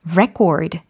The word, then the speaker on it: record: 특히 이 명사로 기록이라는 단어의 발음이 어땠죠? record 였어요. 그리고 강세가 이름절이었어요. record. 그쵸? 근데, 어, record. 이것도 있거든요. 이때는 동사입니다. 그리고 의미도 기록하다 라고 바뀌고요. 그리고 이음절 강세로 강세도 바뀌어요. record, record. 네, 이렇게 비교해 두시면 좋겠어요.